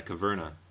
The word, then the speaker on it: Caverna: Caverna? – Yeah. – Is that how you pronounce that? – Yeah, Caverna.